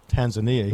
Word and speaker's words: Tanzania: Tanzania is the way they pronounce it there. – I mean, we always said it Tanzania here.